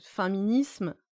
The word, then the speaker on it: Faiminisme: Donc, ça s'écrit F-A-I-M-I-N-I-S-M-E, et les gens me disent ça se prononce féminisme ou féminisme. Je suis là, maintenant je dis comme vous voulez. Mais encore une fois, on a faim, donc féminisme.